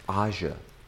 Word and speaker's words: Asia: Aja.